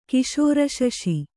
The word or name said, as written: ಕಿಶೋರಶಶಿ